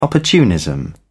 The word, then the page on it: opportunism: British English